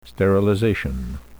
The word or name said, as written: sterilization